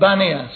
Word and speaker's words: Banias: And so it became Panias, but pronounced by them Banias.